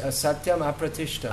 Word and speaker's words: asatyam apratishtam: It's not supposed to be joined. It's it can be in the Sanskrit, but it's pronounced asatyam apratishtam.